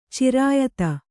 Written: ಚಿರಾಯಿತ

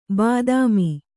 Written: ಬಾತ್ಮಿ

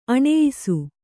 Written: ಅನಾಯಸ

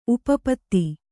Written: ಉಪಪದ

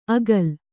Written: ಅಗಲ್

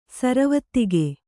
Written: ಸರವತ್ತಿಗೆ

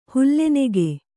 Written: ಹುಲ್ಲೆನೆಗೆ

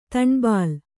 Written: ದನಬಳ

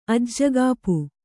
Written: ಅಜ್ಜಗಾಪು

